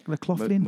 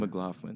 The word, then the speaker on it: McLaughlin: McLaughlin. – McLaughlin.